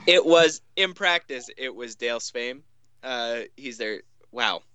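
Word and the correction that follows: Sveum: Dale Swaim um, is their hitting coach and is a former manager.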